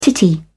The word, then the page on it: titty: British English